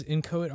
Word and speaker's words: Inchoate: It was Inchoate Art Gallery. That's how you say it.